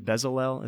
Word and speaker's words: Bezalel: I think we would pronounce it Bezalel. Is that correct? – Yeah, yes, absolutely.